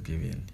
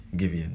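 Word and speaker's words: Giveon: Did I say his name right? It's Givian. Givian, yeah, the one that has Givian.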